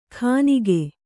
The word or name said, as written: ಖಾನಿಗೆ